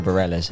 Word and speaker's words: Bareilles: Is that how you say it? – That's how I say it. Sarah Bareilles.